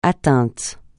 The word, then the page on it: atteint: francais